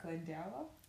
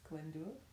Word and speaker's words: Owain Glyndwr: I'll read it like Glendower. – Yeah. – But the way you're supposed to pronounce it is Glyndwr.